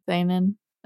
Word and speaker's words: seinen: Seinen.